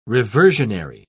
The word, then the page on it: reversionary: British English